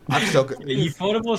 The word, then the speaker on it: Ufotable: Actually, I googled it while we were talking about it. It's actually pronounced UFO table. Spikey was right.